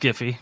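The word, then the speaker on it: GIF: Somebody actually shared a Jiffy of Thanos in that conversation, too. – Jiffy? – It's pronounced Giffy. – Jiffy. It's Jiffy.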